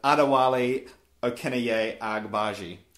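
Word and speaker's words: Adewale Akinnuoye-Agbaje: Adewale Okinaye Agbaji, who's preferred to just as Adewale.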